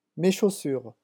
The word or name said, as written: mes chaussures